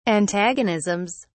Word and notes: antagonisms: British English

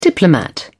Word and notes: diplomat: British English

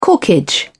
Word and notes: corkage: British English

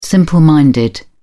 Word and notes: simple-minded: British English

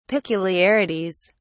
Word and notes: peculiarities: British English

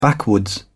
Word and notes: backwoods: British English

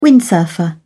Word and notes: windsurfer: British English

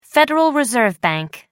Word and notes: federal reserve bank: American English

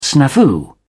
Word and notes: snafu: British English

